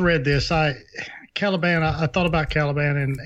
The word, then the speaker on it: Caliban: Caliban.